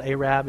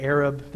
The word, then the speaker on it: Arab: I didn't mispronounce that. It's actually called Arab. If you say Arab, they'll correct you.